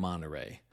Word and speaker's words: Monterrey: This is a different Monterrey. Although pronounced the same way, they are spelled differently. Monterey, California has one R in it, Monterrey, Mexico has two R's.